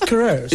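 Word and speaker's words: Queiroz: That's I told you, but it's K-I-R-O-Z or R-O-Z. Just Quarez.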